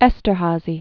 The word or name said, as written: Esterhazy